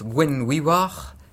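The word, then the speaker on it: Gwenhwyfar: Guenièvre, l'âme, en celtique son nom se prononce Gwenwiwar, c'est-à-dire blanc fantôme.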